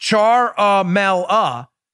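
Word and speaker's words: Ciaramella: He says further, Charamella, pronounced Char-a-mel-a, left his National Security Council posting in the White House West Wing in mid-2017.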